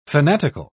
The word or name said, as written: fanatical